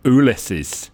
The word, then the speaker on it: Ulysses: That was the verdict of the magazine Teacher's World upon the first publication of Ulysses by James Joyce, which apparently Joyce himself always pronounced Ulysses.